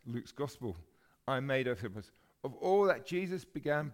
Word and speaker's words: Theophilus: most excellent Theophilus. That's how you pronounce it.